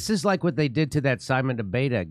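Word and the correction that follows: Ateba: So, Simon DeBeta is a African journalist. – Atiba. – Is that how you say his name? Yeah. Okay, I'm butchering. Okay, I'm butchering his name.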